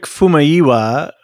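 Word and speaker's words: Famuyiwa: It's Rick Fumaiwa.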